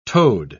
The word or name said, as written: toed